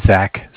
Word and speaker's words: Zach: Okay, my name is Zach, spelled Z-A-C-H. I'm calling from the Bank of Cardiff. Cardiff is spelled C-A-R, like car, D-I-F-F, like different.